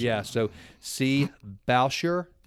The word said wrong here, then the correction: Boucher: Did I pronounce that right, Mike? – No, Connie Boucher. – Boucher. See, that's an East Texan trying to pronounce a French accent.